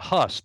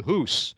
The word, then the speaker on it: Hus: And of course, he shortened it from Husinec to Hus. – Right. – So of course, Hus rhymes with goose.